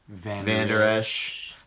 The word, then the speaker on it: Vander Esch: Leighton Vander Esch.